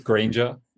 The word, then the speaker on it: Grainger: It's slightly wrong. It's Grainger.